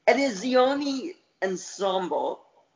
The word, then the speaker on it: Edizioni Ensemble: And there'll be another full length collection in Italian by, I hope I pronounce this correctly, Edizioni Ensemble, uh, in Italia uh, will be published. – So that'll be in spring. Did I pronounce that correctly? – Pretty good. Pretty well, yeah.